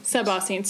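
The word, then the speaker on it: suboscine: Am I pronouncing that correctly? – Sub-ocene.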